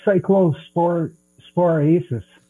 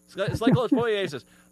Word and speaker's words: Cyclosporiasis: All right, if you don't mind, and I don't, how do you say this? Cyclospasasis? – Cyclosporiasis. – Cyclosporiasis.